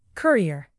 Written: courier

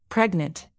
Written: pregnant

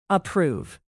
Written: approve

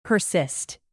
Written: persist

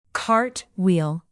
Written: cartwheel

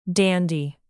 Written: dandy

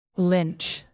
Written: lynch

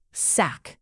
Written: sack

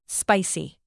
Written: spicy

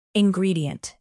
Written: ingredient

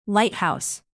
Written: lighthouse

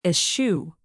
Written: eschew

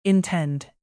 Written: intend

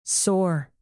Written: soar

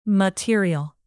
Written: material

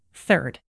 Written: third

